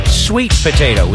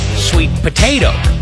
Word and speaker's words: sweet potato: See, I say sweet potato with the emphasis on the word sweet. He said sweet potato.